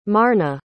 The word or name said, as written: marna